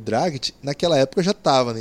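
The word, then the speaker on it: Dragić: Dragic, que é o nome certo, eu sempre pronunciei errado, depois que eu aprendi isso.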